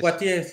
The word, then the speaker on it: Poitiers: La batalla de Poitiers, creo que lo estoy pronunciando bien, o Poitiers. – ¿Qué Poitiers, sí, exactamente.